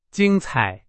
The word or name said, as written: 精彩